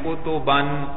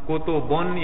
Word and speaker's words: کتباً: کتباً, کتبن یا کتبن نہیں پڑھ رہے بھائی.